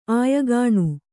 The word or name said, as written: ಆಯ್ಕನೆ